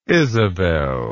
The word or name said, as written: isabel